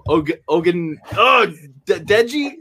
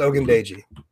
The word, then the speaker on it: Ogundeji: And then the last name is pronounced Ogundeji.